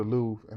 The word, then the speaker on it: Louvre: The Louvre.